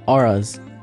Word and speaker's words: auras: auras. I was saying auroras when in reality it was supposed to be, I was trying to talk about auras, so I just wanted to clear that up and also go back into it.